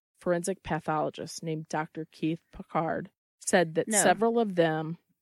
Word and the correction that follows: Pincard: Pincard. Yeah. There is an N in there.